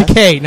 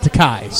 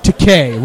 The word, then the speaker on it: Takei: It's Takei, not Takei. – Sorry. – George Takei rhymes with gay.